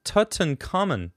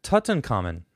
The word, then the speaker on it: Tutankhamun: Also, I believed it's pronounced Tutankhamun. Tutankhamun.